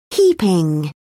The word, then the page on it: heaping: British English